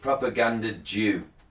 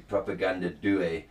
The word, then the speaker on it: Propaganda Due: And speaking of mispronouncing, I thought this was called Propaganda Jew, D-U-E. Then I realised it's an Italian conspiracy, and it's actually Propaganda Due. The Italian word for two.